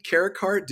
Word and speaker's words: Carricarte: So today we have LIndsay Carricarte.